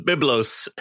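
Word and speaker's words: Byblos: The first thesaurus can be traced back to a Greek grammarian named Philo of Bilbos. I might be saying that wrong. – Biblos. – Biblos.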